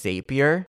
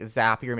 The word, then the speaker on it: Zapier: They did a good job, but they kept pronouncing it as Zapier. And I just cannot get out of this situation. Like, Zapier makes you happier. It's a zap.